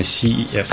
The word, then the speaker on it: CES: А вот в комментариях к роликам люди пишут нам, что мы колхозники говорим CES. Надо говорить правильно CES.